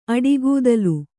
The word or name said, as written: ಅಡಿಗೂದಲು